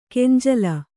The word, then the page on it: ಗಂಜಳ: kannada